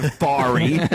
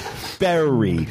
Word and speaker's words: fairy: No, it's not furry. It's fairy. Fair.